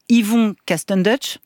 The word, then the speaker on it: Yvon Castendutch: Votre personnage de flic foireux, je l'ai dit, s'appelle Yvon Castendutch, je le prononce bien ? – Oui, très bien.